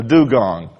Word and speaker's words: dugong: It's spelled D-U-G-O-N-G, a dugong, and that's how you pronounce it.